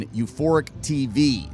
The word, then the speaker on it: Euphoric TV: you can watch the headliner sets on Euphoric TV. It's basically through their YouTube channel. I think that's how you say it. It's U, the letter U, Phoric, Phoric TV, Euphoric TV.